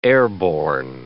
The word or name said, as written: airborne